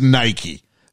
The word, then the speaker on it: Nike: The film did give us the definitive pronunciation of the brand. – Yeah. – It is Nike. – Which I just say Nike. I've always said Nike. – Yeah. – But Nike is what they said in the film.